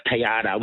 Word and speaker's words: tagliata: The Italians, this is very Italian inspired, and it's a ribeye steak tagliata, which is T-A-G-I-A-T-A, pronounced tagliata, which is a very thinly sliced piece of meat.